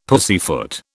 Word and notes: pussyfoot: British English

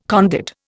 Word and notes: conduit: Indian

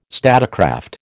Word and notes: statecraft: American English

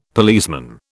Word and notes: policeman: British English

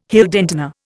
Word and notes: Heldentenor: Indian

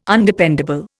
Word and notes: Undependable: Indian

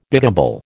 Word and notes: biddable: American English

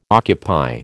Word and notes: occupy: American English

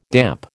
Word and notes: damp: American English

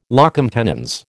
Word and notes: locum tenens: American English

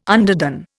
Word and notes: underdone: Indian

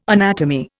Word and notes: anatomy: Indian

